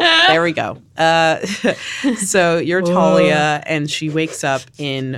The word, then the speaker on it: Thalia: And it's this very pixelated sort of 8-bit looking game where you play as a girl named Talia, I think that's how you would pronounce it. It's T-H-A-L-I-A.